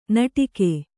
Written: ನಟಿಕೆ

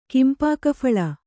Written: ಕಿಂಪಾಕಫಲ

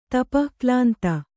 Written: ತಪಃಕ್ಲಾಂತ

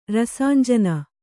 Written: ರಸಾಂಜನ

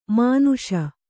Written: ಮಾನುಷ

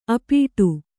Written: ಅಪ್ಪಟೆ

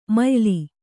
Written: ಮಯ್ಲು

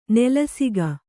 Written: ನೆಲಸುಗೆ

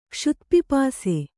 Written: ಕ್ಷುತ್ಪಿಪಾಸೆ

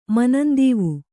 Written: ಮನಂದೀವು

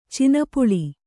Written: ಚಿನಪುಳಿ

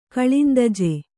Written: ಗೋಲಂದಾಜು

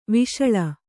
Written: ವಿಶಾಳ

